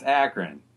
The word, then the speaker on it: Akron: Did I just say Akron? – It's Akron. I'm pretty sure.